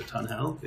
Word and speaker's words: Tunhell: It's called Tunnel, but it's spelled T-U-N-H-E-L-L, so Tunnel.